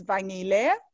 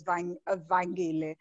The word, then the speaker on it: Vanyile: It's a silent G though, so it's Vanyile. But if your Xhosa, most Xhosa people pronounce it as Vanyile.